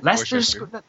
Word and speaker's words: Leicester Square: Am I pronouncing that right? Leicester Square? – Leicester Square. – Sorry? – Leicester Square, isn't it? – Leicester Square. That's how you pronounce it.